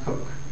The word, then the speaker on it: Coke: Okay, Sir Edward Coke, spelled like Coke, pronounced Cook.